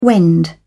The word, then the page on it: wend: British English